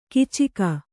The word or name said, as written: ಕೀಚಕ